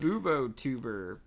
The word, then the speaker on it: Bubotuber: Bubotuber pus.